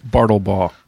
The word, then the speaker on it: Bartlebaugh: Is that how you say your last name, Nathan? – It's close enough. Close enough. Bartlebaugh. – Bartlebaugh. Bartleball. Dude, that is awesome. – Bartleball yeah, it's a German Dutch, or that's what I've been told.